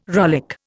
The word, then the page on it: rollick: Indian